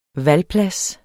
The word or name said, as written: valplads